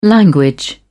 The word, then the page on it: language: British English